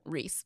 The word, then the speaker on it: Rhys: Yeah, it's like, that's the kind of stuff you get where it's like, we're in classy, we're having a second one, we're naming her Reese, but it's spelled R-H-Y-S.